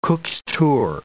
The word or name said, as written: Cook's tour